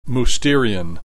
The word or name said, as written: Mousterian